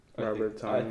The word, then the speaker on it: Robert Tonyan: Robert Tonyan.